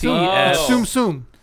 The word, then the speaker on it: Tsum Tsum: It's Tsum Tsum.